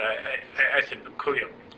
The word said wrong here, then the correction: Kaliuk: That's not correct. – Kaliuk. – Kaliuk. – Yeah. Kaliuk.